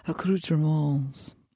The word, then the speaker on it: accoutrements: We would call those accoutrements. – To go. – And you don't pronounce the S, but fuck you, Grammar Dragon.